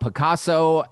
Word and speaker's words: Pacaso: He's the CEO of Picasso and it is spelled Pacaso.